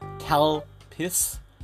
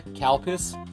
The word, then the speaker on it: Calpis: It's pronounced Calpis, but as you all know, all us English speakers out there, Calpis. So you're drinking cow piss.